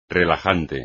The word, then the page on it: relajante: espagnol